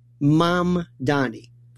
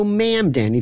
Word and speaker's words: Mamdani: Three syllables, mom, like mom and dad, Donny, like Donny Osmond, Mamdani. Simple, Mamdani. You might even throw ma'am, Danny, if you want to go more upstate, which is fine, too.